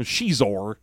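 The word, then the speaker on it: Xizor: So he's got a broken chair so that we can learn that his name is pronounced with a soft X. – There you go. – So it might as well be spelled S-H-E-Z-O-R. – But Shizor.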